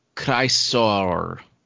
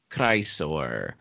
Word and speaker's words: Chrysaor: Chrysaor, the giant. – What is that? – Chrysaor.